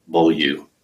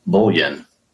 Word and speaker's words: Bolieu: So I'll answer it to anything, but it looks like Bolieu. Or sometimes the U, people mistake it for an N, and I'm Bolien. I'll just say, yeah, I guess that's me.